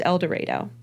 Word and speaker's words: El Dorado: And it is pronounced El Dorado, so don't come for me. I know that one for a fact. I know I mispronounce a lot of stuff, but that one's not one.